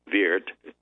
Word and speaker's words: Weert: Some sources mentioned a fair that was to take place in, I believe it's pronounced Weert, W-E-E-R-T, in the province of Limburg.